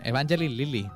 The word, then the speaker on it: Evangeline Lilly: Evangeline Lilly.